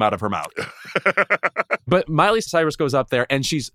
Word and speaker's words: Miley Cyrus: And by the way, Mariah Carey, how do you not pronounce Miley Cyrus correctly? What is happening there? She said Murray Cyrus or something.